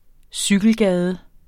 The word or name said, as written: cykelgade